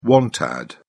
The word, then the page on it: want ad: British English